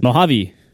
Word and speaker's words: Mojave: My buddy Sam Gross tweeted sometime in the last week, hey, messages in macOS Mojave. I always second guess it. – Mojave. – Long E.